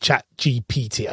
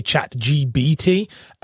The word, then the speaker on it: ChatGPT: I have to make sure that I say that correctly because I suck at the word ChatGPT. I always say ChatGBT and catches me out all the time.